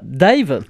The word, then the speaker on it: DIIV: Mais ça s'écrit D-I-I-V. Mm. Donc vous le prononcez comme vous voulez.